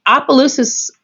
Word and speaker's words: Opelousas: And I Opelousas, okay, so most people don't know how to pronounce it.